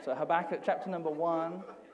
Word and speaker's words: Habakkuk: Now, there's several ways, depending on what country you, you come from. This depends on how you're going to pronounce this this morning, but we, in our, my humble abode, we call it Habakkuk.